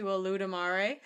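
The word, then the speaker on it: Olodumare: Can we allude to Oludamare? It's not spelled like that, but that's how it sounds.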